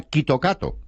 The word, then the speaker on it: Kit Kat: Entre otras razones, porque se pronuncia Kitokato. Que es muy parecido a Kitokatsu, que significa ganaremos con seguridad.